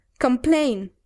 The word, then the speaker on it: complain: Sin embargo, cuando encuentras esa combinación de T-A-I-N en un verbo en inglés, casi siempre se pronuncia como tain. Por ejemplo, el verbo quejarse en inglés. – Quejarse. Mm. Complain.